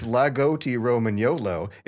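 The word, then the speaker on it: Lagotto Romagnolo: The Romagnolo Lugoto Romagnolo, pronounced Lagotti Romagnolo, is a breed of dog from northern Italy that traces its origin back hundreds of years.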